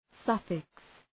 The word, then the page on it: suffix: ‘sʌfıks